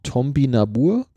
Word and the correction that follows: Topinambur: sagt man das so? – Topinambur.